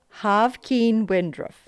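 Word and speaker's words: Haqvin Wijndruf: It's H-A-Q-V-I-N W-I-J-N-D-R-U-F. – I will trust however you say to pronounce that. – Looked it up. Havkin Windruff.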